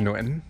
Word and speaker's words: Nguyen: Hello, my name is Joseph Nguyen.